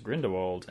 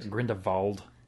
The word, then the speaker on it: Grindelwald: One thing I learned There's a V in it. I was just Grindelwald, and everyone was really putting the emphasis on the V in the movie.